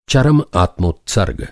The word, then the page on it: आत्मोत्सर्ग: Hindi